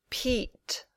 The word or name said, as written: Pete